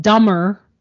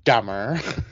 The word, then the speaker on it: Dummer: To be fair to him, it's spelled D-U-M-M-E-R, but, you know, yeah. When you're saying it, nobody really knows that.